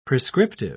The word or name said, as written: prescriptive